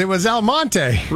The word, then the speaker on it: Almonte: I figured out what Elmont was. Exclamation point, exclamation point. – Elmonte? – It was Elmonte. – Really? – Huh. Yeah. So it's spelled Almonte. Tricky little town.